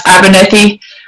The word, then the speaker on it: Abernethy: I love the way you guys pronounce Abernethy, the rolling of the R's and all that.